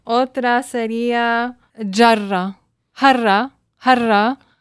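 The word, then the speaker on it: jarra (جرة): El árabe lo pronuncia más como la J catalana y obviamente estamos hablando castellano, lo pronunciamos como jarra, pero lo pronunciaste más al estilo catalán.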